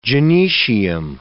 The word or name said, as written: gynoecium